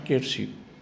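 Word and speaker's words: Quierzy: cittadina francese che si pronuncia Chiersi.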